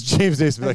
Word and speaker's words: Naismith: Yes, it's James Naismith.